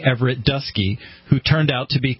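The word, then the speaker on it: Dutschke: It's spelled Dutchke, but I read the GQ article, and Apparently it's pronounced Dusky.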